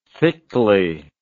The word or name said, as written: thickly